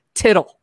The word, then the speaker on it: TTL: It's TTL. That's pronounced tittle. So he said we should all call it tittle.